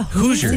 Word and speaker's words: Hoosier: How do you say that? – Hoosier. – Hoosier? – Hoosier? Mm-hmm. I definitely would have said Hoosier.